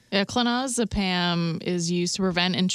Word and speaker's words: clonazepam: It sounds like the drug was clonazepam. I don't know if I'm saying that right. – Yeah, sounds right.